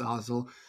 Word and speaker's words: Otzelberger: New basketball coach, TJ Otzelberger. I think it's Otzelberger, yeah. Right? That's how it's pronounced. Yeah. I've heard Otzel, Otzel. I believe it's Otzel.